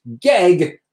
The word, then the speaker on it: GEG: That's pronounced GEG for anyone who's not sure, just like your eggs.